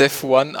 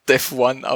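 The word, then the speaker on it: Devuan: Es wird Devuyan ausgesprochen, habe ich vorher gerade gelesen.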